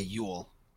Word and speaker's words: Nielsen: I know we had an article that Dylan wrote for Thomas Jule Nielsen towards the end of last season. – It's pronounced Yule. – Okay, Yule. I like saying Jule. Jule just sounds cooler.